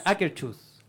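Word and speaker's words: Akershus: No, el Akerus. ah, Akerchus. – Ah, no sé cómo se pronuncia, pero es...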